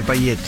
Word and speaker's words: paillet: Payet, mira acá, ¿cómo se escribe payet? – Payete, con doble L. – Con doble L, porque es francés, payet.